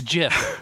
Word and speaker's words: GIF: It's Jif. – It's Gif. – it's Jif. – Yes, yeah. The pronouncer, the inventor of the Jif, would like you to know it's pronounced with a soft G.